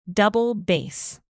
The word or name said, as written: double-bass